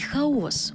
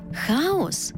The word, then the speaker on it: хаос: До речі, то як правильно наголошувати – хаос чи хаос? Наголос у цьому слові залежить від його значення.